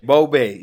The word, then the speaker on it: Bobe: Tell me if me say it right. – Bobe, yeah.